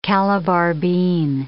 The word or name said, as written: Calabar bean